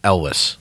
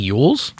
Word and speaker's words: Elwes: I heard that you're supposed to say it like Elvis with a W. Cary Elwes.